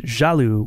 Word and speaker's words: jaloux: One quick note, Galou in French rhymes with the word jaloux, which is jealous.